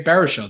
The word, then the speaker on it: Baruchel: Hey, that also includes that you just announced a proud Canadian, Jay Baruchel. Did I say his name right? – You did, yeah, you did.